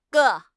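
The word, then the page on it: ge4: tone 4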